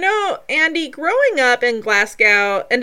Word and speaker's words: Glasgow: So you kind of forget the W at the end, and it's like two words, Glasgow.